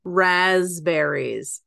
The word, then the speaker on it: raspberries: Raspberries. Okay. – Yeah. – So Danielle, the SC realtor, pointed out that Cody pronounces the P.